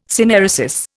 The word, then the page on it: synaeresis: Indian